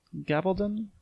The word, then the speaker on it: Gabaldon: Gabaldon? – G-A-B-A-L-D-O-N.